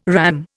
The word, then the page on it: RAM: Indian